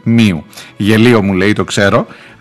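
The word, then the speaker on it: nu: Όμως οπουδήποτε εκτός Ελλάδας και Κύπρου η προφορά τους δεν είναι όπως ίσως θα νόμιζε κάποιος εδώ. Έχουμε, έχουμε λοιπόν μου προφέρεται μίου και νου προφέρεται νίου ε,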